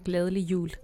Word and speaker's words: glædelig jul: Gledelig jul. – How do you say that? – Gledelig jul. – Gledelig jul. – Gledelig jul. – Gledelig jul. But in Danish, glædelig jul.